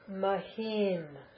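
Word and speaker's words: Mahim (महीं): Mahim, long e, with an anuswara on the top, which is an m.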